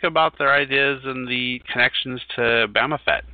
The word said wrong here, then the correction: Baphomet: And I'm sorry, I'm blowing the name totally. I've never been able to pronounce it, but... – Well, he's your leader. You don't even know how to say his name. I am offended. – it's another one of those French things. It's Baphomet is how, if you drink Starbucks, that's probably how you pronounce it, but...